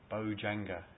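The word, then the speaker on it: bojjhaṅga: And so the teaching that I want to focus on today, the teaching of the seven factors of awakening, Bojanga, it's called, or that's the approximate pronunciation of the Pali word.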